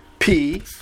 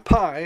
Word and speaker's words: pi: P, not pi, as the mathematicians often pronounce it. P as in pig.